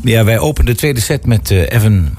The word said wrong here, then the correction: Eran: haar Evan. Zeg ik dat goed zo? Even haar even. – Eran Eran haar even.